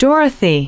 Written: dorothy

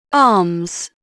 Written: arms

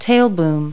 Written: tail boom

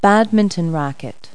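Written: badminton racket

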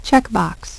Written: checkbox

